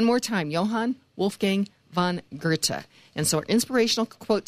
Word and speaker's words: Goethe: Again, it's Johann Wolfgang von Goethe.